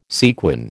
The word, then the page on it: sequin: American English